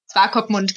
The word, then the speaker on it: Swakopmund: Swakopmund, genau, so spricht man es aus.